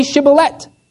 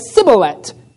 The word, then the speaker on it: Shibboleth: When he said no, they said to him, then say Shibboleth. And he said Sibboleth, for he could not pronounce it right.